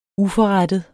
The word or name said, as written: uforrettet